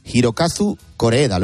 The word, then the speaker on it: Hirokazu Koreeda: Una dramedia del director y guionista Hirokazu Koreeda.